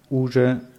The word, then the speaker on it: уже: Ja, уже в аэропорту. – Да. Я уже в Bei уже ist die Betonung auf je.